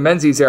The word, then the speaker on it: Menzies: Yes, I said that correctly. Marvin Menzies era, as Shamari Allen was able to have a nice game to begin the season.